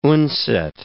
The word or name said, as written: Undset